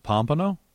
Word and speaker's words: Pompano: It's in Pompano Beach. – You said Pompano? – Pompano? How do you say it? – Is it Pompano? – Pompano. That's right.